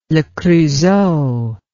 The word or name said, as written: le creusot